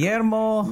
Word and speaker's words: Guillermo: Guillermo del Toro.